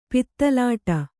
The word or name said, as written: ಪಿತಲಾಟ